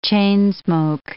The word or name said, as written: chain-smoke